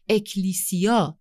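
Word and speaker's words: اکلیسیا (ἐκκλησία): معنای واژه کلیسا در زبان یونانی که اکلیسیا تلفظ میشه یعنی گروهی از افراد دعوت شده برای بیرون رفتن.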